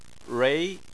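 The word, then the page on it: rui: tone 2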